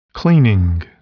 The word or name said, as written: cleaning